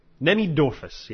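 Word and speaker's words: Cnemidophorus: Nemidorphus, yeah, that's how it's pronounced.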